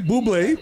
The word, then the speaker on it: Bublé: So Mike Bublé, which shout out to you because you said his name right. I've been calling this nigga Mike Bubbly for the longest.